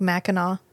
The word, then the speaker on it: Mackinac: It's like Mackinac. – Yeah. – The last N-A-C is silent.